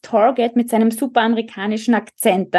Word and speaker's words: Target: Mami, das heißt Target, nicht Target.